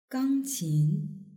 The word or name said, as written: gangqin